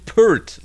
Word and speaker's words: Peart: There's an interview with Geddy Lee, and in the interview, he is being asked, how do you pronounce Neil's name? And he says, Neil Peart, like a bird.